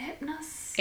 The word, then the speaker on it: Hypnos: There's actually a scene in it where Hera, which we all know, just talked about it, Zeus's wife, asks, oh, I can never say his name. Hypnos. – Hypnos. – Hypnos. Is it Hypnos? – Hypnos. – Yeah, Hypnos.